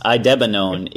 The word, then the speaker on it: Idebenone: It's pronounced Idebenone.